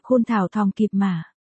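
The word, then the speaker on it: Thao Thong Kip Ma: Khôn Thao Thong Kiếp Ma, nữ quan Thong Kiếp Ma, đọc là Khôn Thảo Thong Kiếp Ma.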